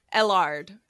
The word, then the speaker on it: Ellard: Ellard. – Ellard. Yes, perfect. Ellard. Just like that.